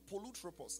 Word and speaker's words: polutropos: Now, that is another Greek word called polutropos. I'll spell that for you. P-O-L-U-T-R-O-P-O-S.